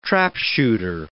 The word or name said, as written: trapshooter